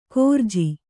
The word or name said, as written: ಕೋರ್ಜಿ